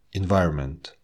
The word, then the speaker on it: environment: Когда в беглой речи мы слышим эти слова, буква N просто съедается. Government, environment, entertainment. Она не произносится вот э, уж точно в слове government, environment.